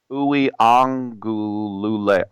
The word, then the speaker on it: Uiagalelei: Actually, you're supposed to pronounce every syllable. So it's Uiagalelei.